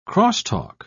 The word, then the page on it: crosstalk: British English